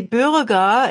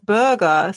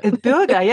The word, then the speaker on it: Bürger: And only recently a colleague or somebody working, a co-worker, told him, it should be Bürger instead of Burger. So it's a pronunciation. – Oh, that's a rough one, the Burger. – Bürger, yeah.